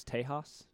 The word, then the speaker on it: Tejas: That's how you say Texas, Tejas.